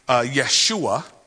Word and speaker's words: Yeshua: Jesus would actually be Yeshua, if it was being pronounced properly.